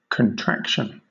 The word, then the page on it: contraction: British English